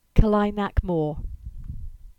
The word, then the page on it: Kelynack Moor: English